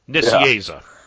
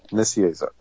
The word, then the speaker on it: Nicieza: It's probably the best pronunciation. – Nicieza. – Nicieza, okay.